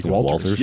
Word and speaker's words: Walters: I wish I could tell people that, you know, my last name is pretty simple, Walters. Mm-hmm. So people are like, how do you pronounce it? Like Walters.